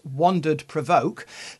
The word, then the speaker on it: WANDRD PRVKE: is the WANDRD PRVKE which I understand is to be pronounced Wandered Provoke, 331, sorry, litre photo bundle Sedona orange camera bag laptop.